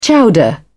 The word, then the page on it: chowder: British English